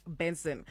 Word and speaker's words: BNXN: Now, if you didn't know who BNXN is, right, it's actually pronounced Benson.